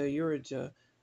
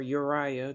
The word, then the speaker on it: Urijah: And King Ahaz sent to Urijah, the priest, or Uriah, depending on how you want to pronounce it, the priest, the design of the altar and its pattern according to all its workmanship.